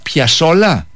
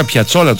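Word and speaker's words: Piazzolla: Αυτό είναι, πώς προφέρεται, Ελ Τσόκλο. – Και Άστορ Πιασόλα. – Σωστότατα. – Μισό λεπτό. Το άκουσα να το λέει. Εγώ τον έλεγα Πιατσόλα τόσο καιρό.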